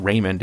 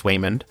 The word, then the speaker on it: Waymond: So essentially, Michelle Yeoh, Evelyn Wang, takes her husband, Waymond, which is his actual name. I actually thought he was mispronouncing it. It's not Raymond, it's Waymond.